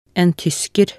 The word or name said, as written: en tysker